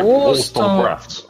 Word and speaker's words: Wollstonecraft: Mary Wollstonecraft. Você tem que estar tipo eu, assim, depois de duas garrafas de cerveja. – Mary Wollstonecraft.